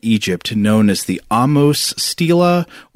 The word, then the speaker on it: Stela: And I already apologize because I know at some point in this episode I'm going to forget to pronounce Stela with a long E and I'm going to start saying Stella. It's that mm-hmm. word, you know, it's S-T-E-L-A or S-T-E-L-E, which I can never get the sounds right in my brain.